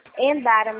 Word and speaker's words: Endara: Ui, desculpa, pronuncia com Endara ou com R? Rendara. – Endara mesmo. – Endara mesmo? Prazer, meu nome é Alexandre, tá?